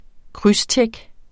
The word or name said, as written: krydstjek